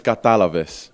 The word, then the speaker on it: Katalavis: Um, so the phrase is "Katalavis." – Katalavis. Yes, bang Did I say it right? You spot on, literally.